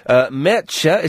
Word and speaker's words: Mertxe: Mertxe is how you pronounce that name, M-E-R-T-X-E.